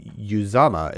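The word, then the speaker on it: Uzomah: On to CJ Uzama is how you pronounce it, from his mouth.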